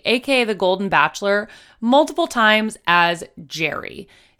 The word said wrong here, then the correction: Gerry: His name is in fact pronounced Gary, despite being spelled G-E-R-R-Y. And I respect that.